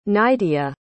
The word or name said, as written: nydia